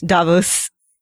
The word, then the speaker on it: Davos: Davos. Pronounced exactly like the character from Game of Thrones and Doctor Who, which a lot of the characters here have connections to.